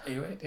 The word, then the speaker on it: Ayoade: Ayoade? – Ayoade, yeah.